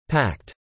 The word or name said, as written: pact